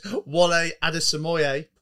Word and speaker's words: Wole Adesemoye: I've been practicing saying this, Wolé Adesemoye.